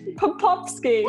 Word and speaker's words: Popovski: I am introducing a friend of mine, also a client, Amanda Popovski.